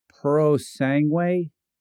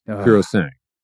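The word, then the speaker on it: Purosangue: You could tell me the Ferrari Puro Sangue? – Nope. Puro Sangue.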